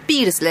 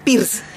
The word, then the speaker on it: Peirce: Peirce le decíamos en el core, un cole público, quieras que no, éramos como una pronunciación más, más pedestre. Peirce le decíamos, pierce.